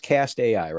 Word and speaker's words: Cast AI: So it is pronounced Cast AI, right? – That's right.